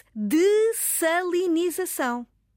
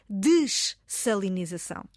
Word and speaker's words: dessalinização: Filomena, eu própria fiquei meio baralhada porque, de facto, se nós na escrita temos dois Ss eu vou soltar, a palavra escreve-se D-E-S-S-A-L-I e, e o resto, denização. Deveria ler-se desalinização. Agora, surpreendam-se, estimados ouvintes, a pronúncia correta, e eu fui investigar, porque tenho bons nós temos bons dicionários de referência, a pronúncia é dessalinização.